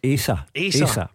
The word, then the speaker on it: Asa: I'll need to pull you up your pronunciation. – Asa. – Oh, is it Asa? – Asa.